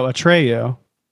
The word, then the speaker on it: Atreyu: The spelling is A-T-R-E-Y-U. – Mm-hmm. Oh, Atreyu. – Like Atreyu? – It's the character from Neverending Story.